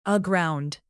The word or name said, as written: aground